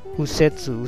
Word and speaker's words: 右折: Pues migi se pronuncia u también. Pues casi siempre un kanji tiene dos tipos de pronunciación diferente. Uno es pronunciación japonesa y otra la china, ¿vale? Entonces aquí viene usetsu.